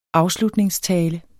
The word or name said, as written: afslutningstale